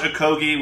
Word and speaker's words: Okogie: So Josh Okogie, which rhymes with Hoagie.